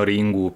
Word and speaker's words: Ringu: Nel senso che in verità è Ring, ma loro lo pronunciano Ringu perché esatto.